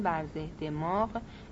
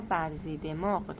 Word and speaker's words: برزه دماغ: توضیح: مترجم از املای درست محله برزه دماغ که سکنه کرمانشاهان برزه دماغ یا برزی دماغ تلفظ می کنند بی اطلاع است و اگر املای این اسم درست نیست درخواست بخشایش دارد، اما این محله هنوز در کرمانشاهان هست.